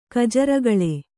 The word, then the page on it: ಗಜರಗಳೆ: gaja ragaḷe